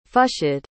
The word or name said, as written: fshd